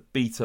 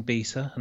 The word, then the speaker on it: B3TA: I'm doing very well, and thank you for correctly pronouncing Beta and not doing Beta or B3TA or one of the other many wrong versions of it, and doing my surname right and not doing Manuel.